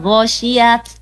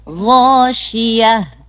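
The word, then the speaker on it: ghoshiyah (غاشية): Hak mati berhentinya. Jadi bukan bukan ghoshiyat ya, tapi ghoshiyah.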